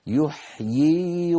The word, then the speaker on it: يحيي: هنا أيضا يحيي بوزن يفعل، يحيي يي ياءان، الياء الأولى مكسورة والثانية ساكنة مدية يي، لكنهم في الخط كتبوها بياء واحدة اختصارا، اختصارا في الكتابة ليس اختصارا في النطق، فالنطق يبقى كما هو يحيي ويميت، سواء وصلنا أو وقفنا، سواء وصلنا أو وقفنا.